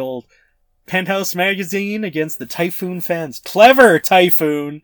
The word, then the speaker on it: Taifun: I'm just realizing right now, I think that's actually supposed to be pronounced Typhoon. It's spelt Taifun. That's what the fan is. But I think it's Typhoon.